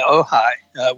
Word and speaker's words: Ojai: But there's still people that, you know, down in Ojai, is that how they pronounce it? – Ojai. – I used to think it was OJ, and I didn't quite understand the connection. But yeah, Ojai, which is where he lived in his latter years, and was a teacher, not a world teacher.